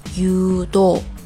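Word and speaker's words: u do (유도): 那柔道这个单词是一个汉字词，它韩文就读成 u do u do u do。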